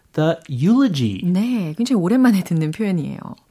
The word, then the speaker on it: eulogy: eulogy 철자를 알려드리면 e u l o g y라는 철자이고 이게 발음이 바로 eulogy 이렇게 하는 거죠.